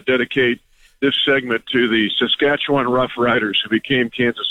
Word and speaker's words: Saskatchewan: So give it up for our Saskatchewan Rough Riders.